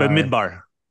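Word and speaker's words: Bamidbar (במדבר): So going in, I mispronounced this, but Bonabar, and it's, that is the meaning of Bamidbar. – Bamidbar. – So say it correctly for us.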